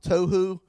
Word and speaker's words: Tohu: The son of Jeroham, the son of Elihu, the son of Tohu, like tofu, the son of Zuph, and he was an Ephraimite.